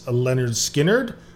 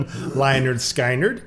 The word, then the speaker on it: Lynyrd Skynyrd: Okay, this week we are featuring the debut album from Southern rock legends Lynyrd Skynyrd, or as I pronounced it for about a good 6 months, when I was listening to them, Lynyrd Skynyrd,